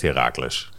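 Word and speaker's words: Heracles: Hoe zeg ik het goed? Herakles. Herakles. Herakles Almelo is het, ja. – Dat is belangrijk toch, dat je het goed zegt? Absoluut. Even, eh, ja, de echte Almelo zegt Herakles. Kijk, dan zeggen wij dat ook vandaag.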